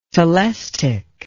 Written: telestich

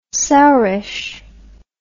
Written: sourish

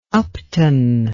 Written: upton

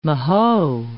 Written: mahoe

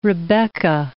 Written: Rebekah

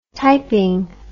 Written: typing

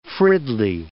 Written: Fridley

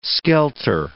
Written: skelter

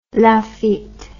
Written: Lafite

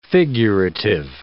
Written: figurative

